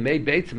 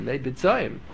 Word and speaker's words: beitzim: If he's going to talk, if he's going to have a poor diction, he's going to say, instead of saying may beitzim, he's going to pronounce it, mispronounce it, may bitzoyim.